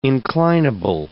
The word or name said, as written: inclinable